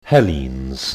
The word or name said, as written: Hellenes